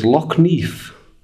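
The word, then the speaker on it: Lough Neagh: We pronounce it Loch Neagh, but Irish people may pronounce it differently.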